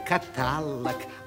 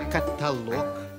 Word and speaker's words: каталог: Не говори каталог, а только каталог.